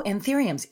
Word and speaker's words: anthuriums: is I, I never know how to pronounce it correctly, so forgive oh, me, uh, Leslie. I'm going to say that off the top. Anth anthuriums? – Oh, anthuriums. That was it.